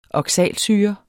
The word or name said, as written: oxalsyre